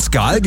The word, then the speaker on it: Skål: Wird Skål geschrieben, Skål aber ausgesprochen.